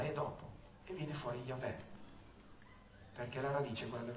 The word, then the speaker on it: Yahweh: Da dove le peschi le vocali per far diventare quella scritta Yahweh? Cioè la A, prima vocale, e la E, ultima vocale. Allora, Yahweh.